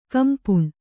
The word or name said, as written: ಖಂಪಣ